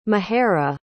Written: mehera

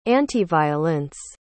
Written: antiviolence

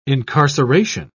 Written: incarceration

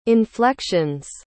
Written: inflections

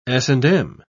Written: s&m

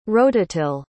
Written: rototill

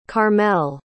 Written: karmel

